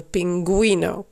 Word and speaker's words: pingüino: Want normaal, als je G-U-I in het Spaans hebt geschreven, dan spreek je het uit als gui. Maar met die puntjes erop wordt het gui. En daarom zeggen we pingüino.